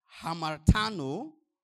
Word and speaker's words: hamartano: These two words are hamartano and hamartia. Hamartano, let me spell it for us in the Greek. H-A-M-A-R-T-A-N-O.